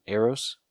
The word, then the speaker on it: Eros: Is that how you say his name? Eros? – Yep.